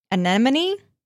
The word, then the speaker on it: anemone: A-N-E-M-O-N-E. – Anemone? – Anemone. – Anemone. – Is that how you say that? Anemone. – It's what Nemo lives in. – Yeah, I didn't know that's how you spell that. – It's an anemone.